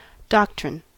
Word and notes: doctrine: American English

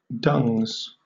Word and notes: dungs: British English

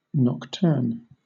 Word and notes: nocturne: British English

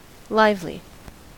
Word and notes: lively: American English

